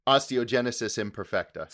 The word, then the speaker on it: osteogenesis imperfecta: Osteogenesis imperfecta.